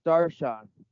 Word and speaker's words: darshan: I think the idea of sitting darshan, I may be saying that wrong. Is that how you know that word, darshan? – Yes, darshan.